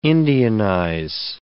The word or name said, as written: Indianize